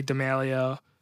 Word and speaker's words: D'Amelio: But she's the biggest TikTok person ever, and as of right now, Charlie D'Amelio. I might have said that wrong, but that's how you say it.